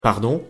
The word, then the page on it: desculpe: frances